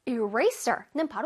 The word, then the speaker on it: eraser: eraser는 바로 지우개입니다. 이레이저가 아니에요. 이레이저, no, no, no, no. eraser. eraser. 자, RA 부분을 선생님이 뚜렷하게 발음한 거 들었어? eraser. eraser. 어깨를 살짝 들썩해줘야 돼. eraser. eraser. 그리고 ser 부분에 설가 아니라 sir 발음이에요.